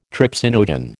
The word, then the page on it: trypsinogen: American English